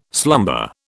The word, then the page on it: slumber: British English